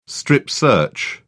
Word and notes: strip-search: British English

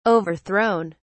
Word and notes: overthrown: British English

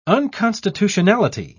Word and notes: unconstitutionality: British English

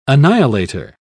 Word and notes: annihilator: British English

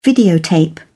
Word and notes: videotape: British English

